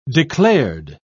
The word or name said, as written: declared